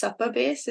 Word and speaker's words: Supabase: So there was a campaign by a company called, I think it's pronounced Supa Base. It's like S-U-P-A Base.